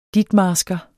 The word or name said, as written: ditmarsker